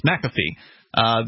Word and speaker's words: McAfee: Apparently that's how it's pronounced, McAfee.